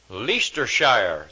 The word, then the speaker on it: Leicestershire: I thought it was Leicestershire. Leicestershire? Huh? Leicestershire. There we go.